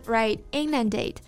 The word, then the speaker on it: inundate: Right, inundate.